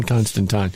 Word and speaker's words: Constantine: Yeah, that's how you really say his name. John Constantine.